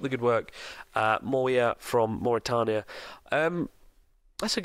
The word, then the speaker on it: Mauritania: And finally, Mawia from Mauritania. Is it pronounced Mauritania? Mauritania. Currently living in Malaysia. – Mauritania, yeah.